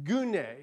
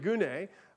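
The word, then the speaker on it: gune (γυνή): the word used for woman right there is gune, is how you'd pronounce it in Greek, gune, and it means woman.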